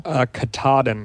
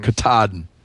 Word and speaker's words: Katahdin: So the Katahdin National Monument, I think that's how you pronounce it. Is that how you pronounce it? – Katahdin. – Katahdin.